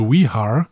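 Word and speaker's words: Vejar: Regie, Regie, dieser Episode hatte Mike WH ähm, geschrieben W-Jahr, aber ich habe mir sagen lassen von Experten, dass es Wehar äh, ausgesprochen wird.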